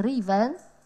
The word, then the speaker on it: Reaven: Reaven się pisze. Czyta się Reaven.